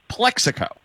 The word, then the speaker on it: Plaxico: It's spelled Plaxico, but pronounced Plexico.